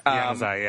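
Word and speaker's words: Januzaj: I'm totally butchered his last name. Yanusai? – Yanusai, yeah.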